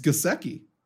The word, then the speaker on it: Gesicki: It's Gesicki. Hard G.